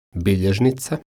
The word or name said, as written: biljeznica